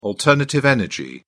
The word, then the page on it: alternative energy: British English